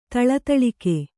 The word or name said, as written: ಥಳಥಳಿಕೆ